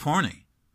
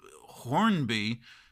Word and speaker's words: Hornby: and the correct pronunciation for the suburb of Horny. I never knew the B was silent. You know, I've been pronouncing it Hornby for the past 30 years.